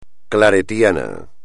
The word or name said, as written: claretiano